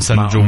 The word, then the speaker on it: San Jose: Puis un de nous deux, au lieu de dire San Jose, qui est la prononciation parfaite, un de nous deux aurait dit San Jose.